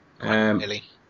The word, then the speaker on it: Guacamelee: Guacamelee. – Yep, Guacamelee.